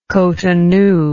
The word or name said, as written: Cotonou